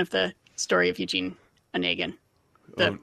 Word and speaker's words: Onegin: We have Onegin. Am I saying that right? – No, Onegin.